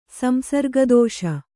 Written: ಸಂಸರ್ಗದೋಷ

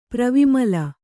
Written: ಪ್ರವಿಮಲ

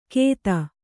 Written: ಖೇದ

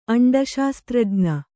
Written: ಅಂಡಶಾಸ್ತ್ರಜ್ಞೆ

